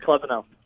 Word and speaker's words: Klebanow: Yeah, it's Jared Klebanow. – I was right. I said Klebanow. I just wasn't sure if it was a long E. I didn't know if I said Shea Klebanow or not.